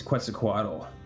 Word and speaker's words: Quetzalcoatl: Lionfish are gorgeously proportioned, elaborately decorated beasts, plumed in the manner of Quetzalcoatl. I can't pronounce that properly, I think. With 18 hypodermic... Yeah. Say it again? – I think it's Quetzalcoatl.